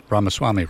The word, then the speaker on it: Ramaswamy: Vivek Ramaswamy. I think you've got Ramaswamy right.